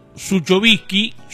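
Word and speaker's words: Zuchovicki: Y días pasados, en lo que fue el día de campo de Charles de Guerrero, en el inicio de la jornada, Claudio Suchovici, así es el apellido, así se pronuncia, estuvo dando su análisis, estuvo haciendo su análisis de cómo ve la situación de la Argentina por estos días.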